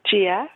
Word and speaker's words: Gia: Gia. – Can you spell that for me? Is it G-I-A? – Uh-huh, exactly.